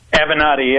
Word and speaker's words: Avenatti: Is it Avenatti? Am I saying it right? – Avenatti, yeah.